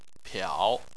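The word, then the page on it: piao: tone 3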